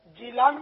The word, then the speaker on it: جیلان: اس کی دو قراءتیں ہیں, دو تلفظ ہیں, جیلان بھی اس کا تلفظ ہے اور گیلان بھی, غین سے غیلان بھی اس کا تلفظ ہے.